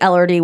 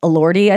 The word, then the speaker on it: Elordi: I pronounced it Elordi when I looked him up the first time. That is wrong. Elordi, I think it is.